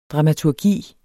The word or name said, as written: dramaturgi